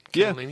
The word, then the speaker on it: Cannellini: Cannellini? Am I saying that right? Cannellini? Yeah. – Yeah, the white ones? – Yeah, yummy. – I was going to say cannoli beans, but that'd be wrong, especially if there was anyone Sicilian listening.